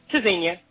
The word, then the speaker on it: Tzynya: Zina, is it? I pronounced that correctly? I'm not sure. Zina or Tzynya? – Tzynya. – Tzynya, okay.